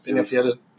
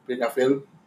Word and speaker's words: Peñafiel: Penafiel. – How do you say it? – Peña. – Yeah. – Fiel. – Peñafiel. – Peñafiel.